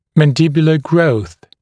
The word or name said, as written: mandibular growth